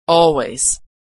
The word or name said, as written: always